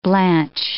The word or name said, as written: blanch